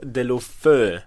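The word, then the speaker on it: Deulofeu: Jag som är maestro och då är mitt offer Gerard Delofeu Uttalas det väl, Sjögren?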